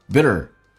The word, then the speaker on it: bitter: 所以没它没有对错，你自己的偏好。During your bitter struggle, bitter, T 发 D 这个发音，轻轻的卷下舌头 ,bitter, bitter struggle.